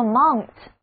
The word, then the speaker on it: mount: 词根 MONT 的意思就是山， MONT 本身也是一个单词，读作 mount， mount，字母组合 OU 读作 ow， ow，这个单词做名词讲的时候就是指那一座座的山，而做动词讲的时候则要表示登山或者爬上、安装。